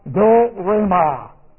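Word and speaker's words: do-re-ma (δώρημα): Now we have do-re-ma. Now watch this. Here's what we have. Do-re-ma. That's D-O-R, long E, M-A.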